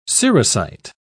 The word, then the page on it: cerussite: British English